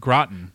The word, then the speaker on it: Groton: Is it Groton or Groton? – Groton. – Like au gratin, the food? – Yeah, right. But G-R-O-T-O-N.